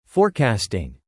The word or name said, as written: forecasting